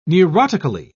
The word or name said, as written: neurotically